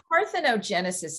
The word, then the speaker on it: parthenogenesis: It's called parthenogenesis.